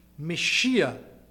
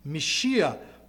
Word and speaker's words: Mashiach: The word in Hebrew I will try to pronounce, it's not going to be a perfect pronunciation, but it's Mashiach. Mashiach, Messiah is the word in Hebrew.